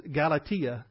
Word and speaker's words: Galatia: And I mentioned this last hour, the actual pronunciation is Galatia.